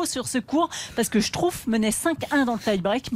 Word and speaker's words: Struff: Je dis Struff parce qu'il y a un auditeur qui m'a envoyé un mail pour me dire que je prononçais mal. Ça s'écrit Struff. – Vous aviez dit quoi ? – Bah, ça s'écrit Struff, en fait, si je veux le prononcer à la française, mais on dit Struff.